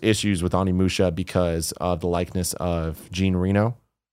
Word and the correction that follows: Jean Reno: Oh, Jean Reno.